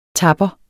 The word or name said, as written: TAPPERNES